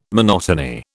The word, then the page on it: monotony: British English